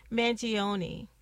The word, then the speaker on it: Mangione: So that came from Chuck Manone. Okay, I already messed up his name the first time, and I even did like a whole pronunciation, but I'm going to say it's Mangione.